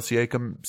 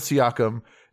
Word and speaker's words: Siakam: Pascal Siakam. – Siakam. – Did I say it right? – No. – Siakam. – You almost said it.